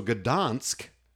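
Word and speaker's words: Gdańsk: So Gdansk is how you pronounce it, apparently.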